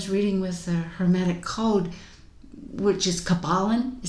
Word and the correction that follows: Kybalion: is that, am I saying that right? – I'm going to say Kybalion. – Kybalion, mm-hmm.